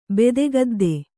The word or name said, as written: ಬೆದೆಗದ್ದೆ